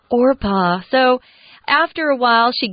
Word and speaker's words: Orpah: Many people constantly mispronounced her name as Oprah instead of Orpah, and the name Oprah stuck.